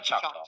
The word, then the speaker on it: Choctaw: Yeah, Choctaw. I was like, I don't say this properly, C-H-O-C-T-A-W.